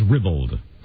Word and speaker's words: ribald: Either one is correct. The first choice is ribald.